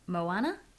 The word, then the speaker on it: Moana: Well, thank you so much, Matt, for reviewing Moana. I'm saying it right, aren't I? – You are saying it.